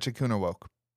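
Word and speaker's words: Echikunwoke: Echikuna woke. Echi? – Yeah, you got it.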